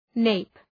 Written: nape

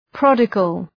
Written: prodigal

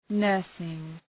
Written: nursing

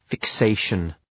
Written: fixation

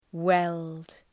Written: weld

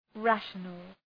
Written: rational

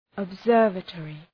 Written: observatory

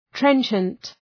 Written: trenchant

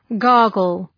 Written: gargle

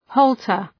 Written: halter

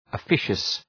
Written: officious

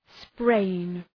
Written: sprain

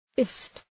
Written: fist

